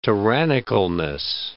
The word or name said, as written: tyrannicalness